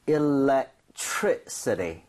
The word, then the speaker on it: electricity: So, electricity. So, 이 정도의 강세를 주고 발음을 하셔야 됩니다.